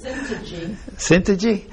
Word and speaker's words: Syntyche: Man, Syntyche. I need those Bibles that tell you how to pronounce it. – Syntyche.